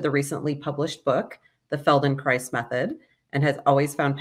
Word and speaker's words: Feldenkrais: In fact, we try to do a service and we say it rhymes with paradise. So Feldenkrais paradise.